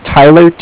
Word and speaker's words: Tyler: My name is Tyler, T-Y-L-E-R, and I'm calling from Bank of Cardiff. It's spelled C-A-R-D-I-F-F, as in Frank Frank.